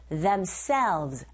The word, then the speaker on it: themselves: 끝발음 하실 때 selves 하지 마시고 themselves. 에! 이 부분에 강세해 주셔서 v 발음만 입술 잡아주시면 돼요.